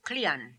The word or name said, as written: Klian